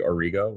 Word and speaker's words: Orego: Yeah, it's technically Orego with a long E.